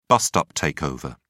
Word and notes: bust-up takeover: British English

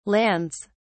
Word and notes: lands: British English

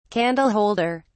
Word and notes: candle holder: British English

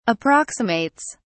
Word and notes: approximates: British English

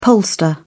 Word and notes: pollster: British English

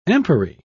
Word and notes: empery: British English

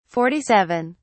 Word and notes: 47: British English

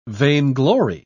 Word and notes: vainglory: British English

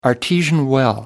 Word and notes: artesian well: American English

